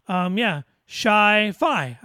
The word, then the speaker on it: ChiPhi: C-H-I-P-H-I. It was a combination. Oh, sorry. Here's how it's actually pronounced. Let me go back. It's pronounced, Chi-Fi. I was correct.